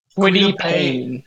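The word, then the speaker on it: Kwity Paye: But I think they're going to take I'm going to take Kwita Paye. I hope I'm pronouncing that right. – Quiddy Payne.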